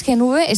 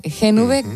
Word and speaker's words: Gen V: A ver, ¿es Gen V? ¿Se dice así? – Gen V, exactamente.